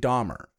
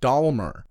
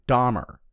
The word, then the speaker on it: Dahmer: But then we have this person who can completely change themselves from some smiling, dancy, uppity teen kid to Jeffrey f***ing Dahmer. Which I didn't realize until a couple years back. I had been pronouncing wrong the whole time. I was saying Dahmer, like there's an L in it, not Dahmer.